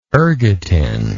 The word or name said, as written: ergotin